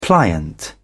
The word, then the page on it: pliant: British English